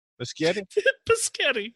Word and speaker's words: Muschietti: How do you say it? Muschietti? – Muschietti. – S-C-H. And it's Italian, so it must be Muschietti.